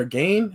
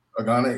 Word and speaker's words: Gane: Is it Gane or Gane? How do you say his last name, this dude? – Gane, yeah. Gane.